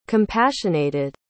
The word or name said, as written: compassionated